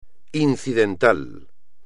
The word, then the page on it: incidental: espagnol